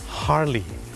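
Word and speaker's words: Harley: ฮาร์ลี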